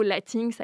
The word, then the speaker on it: latinx: Et du coup, c'est là que le mot « latinx » a été utilisé. Donc, ça s'appelle « L-A-T-I-N-X ». En anglais, du coup, il y a la prononciation du « X » à la fin.